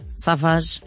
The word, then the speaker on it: Savage: Se tens mandado mensagem com a palavra Savage, em homenagem ao nosso 21. – Savage. Para o 968. Savate. Não, é com G, não te esqueças.